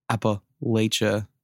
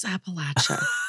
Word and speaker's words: Appalachia: And I've got more Appalachia. – It's Appalachia. Say it right.